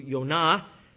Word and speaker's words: Yonah: Jonah's name, which is pronounced in Hebrew Yonah, simply means dove.